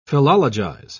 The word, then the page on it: philologize: British English